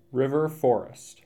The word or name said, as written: River Forest